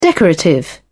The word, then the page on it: decorative: British English